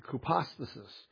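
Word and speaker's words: hypostasis (ὑπόστασις): That's how you pronounce it in Greek, hypostasis.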